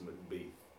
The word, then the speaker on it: Macbeth: He's putting my little bugbear about Macbeth, which is that it's definitely pronounced Macbeth. Because it rhymes with Heath like 10 times during the play.